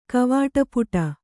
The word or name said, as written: ಕವಾಟಪುಟ